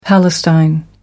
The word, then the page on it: palestine: American English